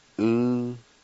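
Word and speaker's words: uwani: Word number 1 is wool, and it's pronounced oo-wa-ni. Oo-wa-ni.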